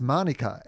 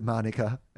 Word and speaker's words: Manuka: But it's spelt Manuka, like the honey might be spelled, but it's pronounced Manuka. And the urban legend, I'm not actually sure if it's true or not, was that the Queen came out for an event and she pronounced it Manuka.